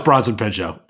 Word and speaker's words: Bronson Pinchot: Oh, it's Bronson Pinchot.